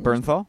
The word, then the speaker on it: Bernthal: Bernthal.